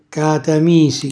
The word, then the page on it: catamisi: calabrese